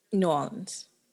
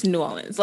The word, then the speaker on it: New Orleans: New Orleans. – That, to me, that's the correct way. – It's one word. It's New Orleans. Like, it's like one word. It's like one syllable.